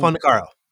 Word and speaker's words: Fondacaro: Phil Fondacaro.